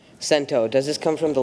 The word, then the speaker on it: cento: Cento is the only pronunciation.